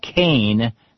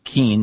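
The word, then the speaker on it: Kean: She says, you're not an East Coaster, so you wouldn't know this, but the former New Jersey governor, a Republican moderate, pronounces his name Kane, not Keen, the way it's spelled.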